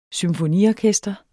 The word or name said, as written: symfoniorkester